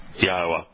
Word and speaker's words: Yahweh: No, it's not pronounced in Hebrew as Adonai. It's pronounced in Hebrew as Yahweh.